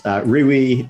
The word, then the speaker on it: Riwi: Is that how you pronounce it? Rewy? – So anything goes. Rewy, R-I-W-I, or R-I-W-I Corp.